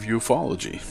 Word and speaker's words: ufology: One of the best documented and certainly most witnessed UFO events in the history of ufology.